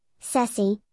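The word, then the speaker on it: CCSSE: The Community College Survey of Student Engagement, pronounced CESE, provides data and analysis about student engagement in community colleges.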